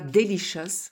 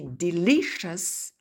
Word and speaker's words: delicious: Famous, nervous, attention à l'accent tonique, jealous, dangerous, delicious, hein, on ne dit pas delicious, on dit delicious, various, curious.